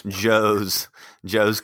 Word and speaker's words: Joe's: Yeah, it rhymes with schmoes. So Joe's Casey.